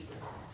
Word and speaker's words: Ibrahim: And also the Arabs do not pronounce it Abraham, it's Ibrahim with a I.